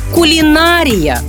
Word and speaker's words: кулінарія: Наголос у слові має бути на третьому складі. Кулінарія.